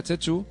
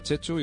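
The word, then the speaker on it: Txetxu: pero lo, voy a, lo tengo que decir porque mi nombre se pronuncia Chechu, pero se escribe con TX porque es de origen, de origen vasco, del norte de España.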